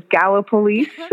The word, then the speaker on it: Gallipolis: I said that correctly. It is Gallipolis, Ohio.